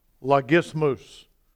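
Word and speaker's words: Logismus (λογισμός): That word arguments in the Greek, I'm going to give you the pronunciation. Tell me what it sounds like. Logismus. What did you say, sir? Yes! Hand in the air. Bam! That's exactly right.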